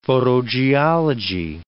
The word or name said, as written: photogeology